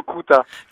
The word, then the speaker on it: Kuta: Alors A Home c'était pas mal, par contre on prononce plutôt Kuta. – Kuta. – En fait. Voilà, ouais, mais tu n'es pas le seul à dire Kuta, beaucoup disent Kuta, mais c'est la prononciation exacte, c'est Kuta.